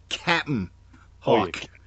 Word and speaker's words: Cap'n Hawk: Well, he's got multiple names, but in that issue, let me say his name properly. It's Cap'n Hawk. – I forgot it was Cap'n. – C-A-P apostrophe N. Cap'n.